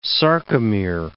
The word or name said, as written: sarcomere